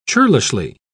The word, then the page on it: churlishly: British English